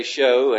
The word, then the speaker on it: shew: Now I recognize it's spelled S-H-E-W, and may be pronounced shew, but we say show, and the way it's used is exactly the same, and the word S-H-O-W is not in the Word of God, but shew is in there many times. But nevertheless, I've pronounced it show, and I hope you'll bear with me.